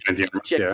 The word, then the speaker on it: Ana de Armas: Oh, it's Ana de Armas, from.